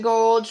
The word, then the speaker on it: Gould: It's pronounced gold, but it's spelled like Gould, G-O-U-L-D.